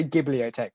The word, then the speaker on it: Ghibliotech: I would say Ghibliotech with a hard G.